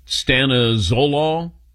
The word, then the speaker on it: Stanozolol: Stanozolol, or Dylan, you got a better pronunciation on that? – That sounds about right.